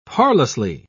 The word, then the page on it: parlously: British English